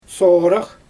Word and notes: soorax: Xyzyl

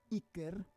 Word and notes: iker: Roon

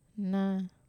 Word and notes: na: Wamesa

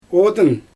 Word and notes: odyn: Xyzyl

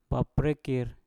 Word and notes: baprekkir: Mpur

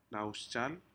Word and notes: nahosjal: Aneityum

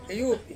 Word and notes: uwupi: Ansus

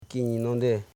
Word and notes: kininande: ki.ni.nan.de

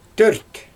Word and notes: tørt: Xyzyl

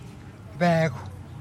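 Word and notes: beecw: Teotitlán del Valle Zapotec

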